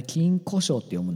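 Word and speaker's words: 金鯱賞: 今週行われる金庫賞ですか。 – そう、あれね、金庫賞って読むんだぜ。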